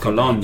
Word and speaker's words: cologne: Well, I've definitely sampled some good colognes in my time, but I don't know if I've sniffed any coffee Cologne. – Beans. – Cologne. I believe it's pronounced. – Right. – Like lasagna.